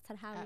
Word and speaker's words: Sahrawi: Did I pronounce it right, Sahrawi? – Absolutely perfect, yeah.